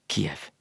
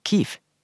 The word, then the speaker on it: Kiev: for det er den russiske uttalen av byen Kiev. Men så har jeg fått greie på nå at den ukrainske uttalen er Kiev.